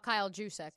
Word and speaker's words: Juszczyk: No, Kyle Juszczyk.